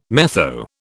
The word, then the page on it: metho: British English